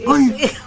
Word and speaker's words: Gone: Como gonet, pero gone.